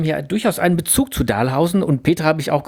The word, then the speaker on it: Dahlhausen: Deine Geschichte spielt in Dahlhausen. Ne? Das nennen die Dahlhausener und Bochumer übrigens Dahlhausen, also auf der ersten Silbe betont.